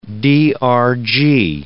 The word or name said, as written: DRG